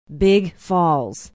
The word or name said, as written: big falls